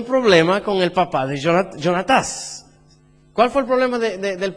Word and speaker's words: Jonatás: ¿Cómo se dice en portugués? Jonatás. En español es Jonatán. En inglés es Jonathan.